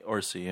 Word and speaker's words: Orci: Fucking all, or Orci, or however you say his name. – Orci, yeah.